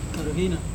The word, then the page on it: karuhina: Serewen